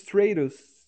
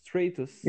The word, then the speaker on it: Stratus: Trish Status. Como que se pronuncia? – Que? – A Trish lá, mano. A tri... Trish, mano. – Trish Stratus. Stratus.